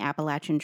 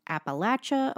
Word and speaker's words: Appalachia: And I also did research on whether it's pronounced Appalachia or Appalachia. So I've always said Appalachia. I found a video on YouTube of author Sharon McCrum. She said Appalachia is the pronunciation of condescension, or said by people who do not want to be associated with the area. And if you say Appalachia, it means you're on the side of trust.